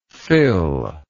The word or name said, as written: Phil